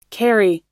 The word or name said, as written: karri